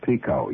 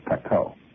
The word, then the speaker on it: Pekoe: It's not pronounced Pico, you know. It's Pekoe.